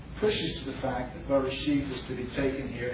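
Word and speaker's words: bereshit (בראשית): The first is, in the Hebrew text, bereshit is accented, that's the word beginning, with a disjunctive accent. So on the last syllable, and disjunctive accents indicate that a word has its own independent accent.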